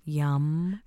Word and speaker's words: yam: The seed sound for this chakra is yum. – Yum. – Spelled Y-A-M. – Oh, okay. – But it's pronounced sort of like a rounded like yum.